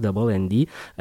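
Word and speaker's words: Dubl Handi: on, on, l'écrit en fait D-U-B-L espace H-A-N-D-I, mais on prononce Double N-D, euh, un, duo de, euh, de Brooklyn, oui voilà, Brooklyn à New York.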